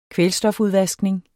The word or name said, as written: kvælstofudvaskning